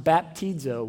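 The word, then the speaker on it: baptizo (βαπτίζω): It's just one word, and it's the word baptizo. We're going to put it up on the screen, and this is what it looks like in the Greek. It's pronounced baptizo. Would you say that with me?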